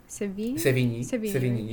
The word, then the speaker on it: Sevigny: Sevigny, yeah.